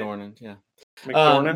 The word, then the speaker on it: McDormand: McDormand.